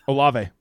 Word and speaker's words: Olave: How do you say his name? – Olave.